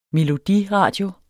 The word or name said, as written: melodiradio